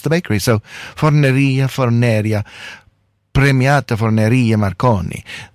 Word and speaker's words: Premiata Forneria Marconi: It is not Premiata Forneria Marconi, as I've been saying. It's Premiata Forneria Marconi.